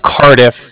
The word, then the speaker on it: Cardiff: Bank of Cardiff. It's spelled C-A-R-D-I-F-F, as in Frank, right? – C-A-R-T... – D as in dog. – C-A-R-D-E-R? – D-I-F-F. So it's like car and then different. So Cardiff. Sounds very funny over the phone, I know. – Bank of C-A-R, let's spell it again.